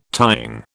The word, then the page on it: tying: British English